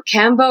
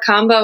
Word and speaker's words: Kambo: People actually say it two ways. So Cambo or combo. And it's funny. Some moments I'll say Cambo and some I'll say combo.